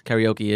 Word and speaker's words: karaoke: The actual real way to pronounce karaoke is Karaoke. – Handle the pronunciation with care. All right? Make sure that the final ke sounds like a ke and not like a key. – Karaoke. – And karaoke is a noun. It becomes a verb when followed by o shimasu or shimasu, which means to do karaoke.